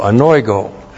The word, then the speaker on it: anoigo: Looks like that in the Greek, A-N-O-I-G, long O, anoigo.